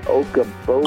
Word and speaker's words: Okoboji: Fish Okoboji.